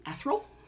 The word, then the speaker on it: ethereal: Say that word again. – Ethereal? Ethereal? – Ethereal. – Ethereal? Ethereal? Ethereal? – Never in my life did I think I would correct somebody on their pronunciation of something. – Ethereal? – Ethereal. – I always said ethereal.